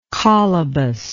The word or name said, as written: colobus